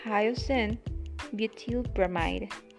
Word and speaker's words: hyoscine butylbromide: Hyoscine butylbromide. Hyoscine butylbromide. ¿Cómo lo conocemos en español? Butiliocina. Pero en inglés lo pronunciamos de manera completa. Otra vez es hyoscine butylbromide.